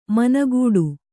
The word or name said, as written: ಮನಗೂಡು